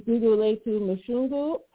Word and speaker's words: Gugulethu Mhlungu: This is Gugulethu Mhlungu.